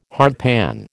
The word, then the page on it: hardpan: American English